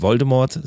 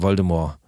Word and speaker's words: Voldemort: Das Einzige, was mich stresst, ist, dass er äh, nicht Voldemort sagt, sondern Voldemort. So ein bisschen französisch ausgesprochen. Also oh. Also ohne das T am Ende, das stresst mich ein bisschen.